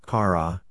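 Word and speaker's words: chara (χαρά): This word, joy, in the Bible and from the Greek language in which it was written, is chara, pronounced, chara, means joy, delight, gladness, a source of joy.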